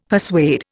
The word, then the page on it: persuade: Indian